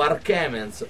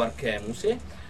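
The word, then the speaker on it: Alchermes: O Arkemens, o Arkemens, o Arkemuse. In varie parti di Perugia si pronuncia in maniera differente,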